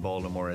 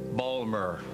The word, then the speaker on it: Baltimore: There was no basketball to speak of in Baltimore at that time, no hockey. Colts absolutely owned the city, particularly since we didn't have anything else to be proud of. People would mispronounce the name, Baltimore, Balmer.